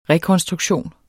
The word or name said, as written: rekonstruktion